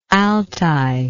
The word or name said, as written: altay